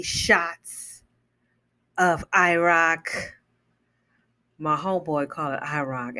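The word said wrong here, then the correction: Cîroc: Hey, you still got some on that IROC? You mean Ciroc, motherfucker? Ciroc? Oh, that's how you pronounce it? Yes, Ciroc.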